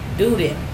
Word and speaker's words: Doden: D-O-D-E-N. Duden.